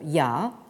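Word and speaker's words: ya (亚): qui se prononce en chinois moderne, mais peu importe, euh, ya.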